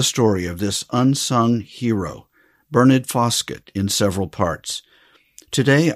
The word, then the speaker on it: Bernard: First, it is spelled B-E-R-N-A-R-D, we would say in the States, Bernard or Bernard. And his last name is Foskett, F-O-S-K-E-T-T. Now, in this country, we say the name Bernard as it is spelled.